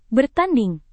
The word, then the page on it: bertanding: Indonesian